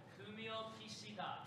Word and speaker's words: Fumio Kishida: According to Japan's Prime Minister Fumio Kishida, did I say that right? – Oh, my God. – What is it? – Fumio Kishida.